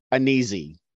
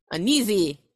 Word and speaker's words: Anizi: It's A N I Z I. – A N I Z I. – Yeah, I liked how it looked. – Uneasy. Uneasy. Yeah.